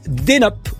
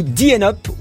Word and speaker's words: DNUP: Alors ça s'appelle Denup ou Dienup ou Dnup. Chacun le prononce comme il veut, mais c'est vrai, en hein, plus, ça s'écrit D-N-U-P.